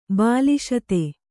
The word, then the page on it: ಬಲಸುತ್ತು: bala suttu